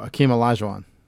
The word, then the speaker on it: Hakeem Olajuwon: Oh, Hakeem Olajuwon.